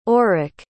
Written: orik

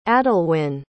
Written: adalwin

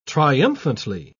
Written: triumphantly

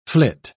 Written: flit

